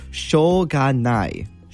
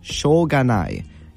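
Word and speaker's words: shoganai (しょうがない): I believe it's pronounced shoganai. Shoganai. Yeah, I said that pretty correctly. Again, if my pronunciation isn't perfect, we're all trying to learn as ambassadors, but I believe it is pronounced shoganai.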